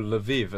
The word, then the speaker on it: Lviv: But I know Lviv as Lvov. There's an issue with Ukrainian versus Russian pronunciation, which is yet to be resolved after the breakup of the Soviet Union, funnily enough.